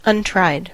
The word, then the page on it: untried: American English